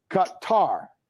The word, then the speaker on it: Qatar: So here's a definitive answer from our own resident expert, our RT Arabic correspondent, Ahmed Al-Hazim, said the correct pronunciation is Qatar. Qatar. Not Qatar, not Qatar, but Qatar, accent for a syllable, as in cutting the tar, cut the tar that is like on your roof.